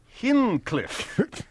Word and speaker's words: Hinchliffe: Could I begin, please, by having the correct pronunciation and spelling of Richard Hinchliffe? – Okay. – Okay. – And the spelling of his last name is H-I-N-C-H-L-I-F-F. F-E. – H-I-N-C-H-L-I-F-F-E. – Right. – Hinchliffe, if you will. Okay, here is the first of the three. Dink. D-I-N-K. Hinchliffe. H-I-N-C-L-I-F-F-E.